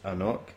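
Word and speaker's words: AnCnoc: Hello, and this drams is an Anknock. – Anknock. – Anknock. – Anknock. – It tells you how to pronounce it. I still got it wrong on the bottle.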